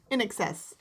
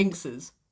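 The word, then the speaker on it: INXS: Or Inkses. – Inkses. No, that would be Inses. – Oh. – Because an X would be pronounced as a Z in the middle of a word.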